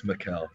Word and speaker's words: Mikal: I think it's Mikal. – It's Mikal. It's definitely Mikal.